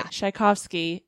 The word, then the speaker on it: Tchaikovsky: So Tolstoy played a song on the piano that apparently sent Tchaikovsky, did I say that right? Okay, yeah. Tchaikovsky into tears.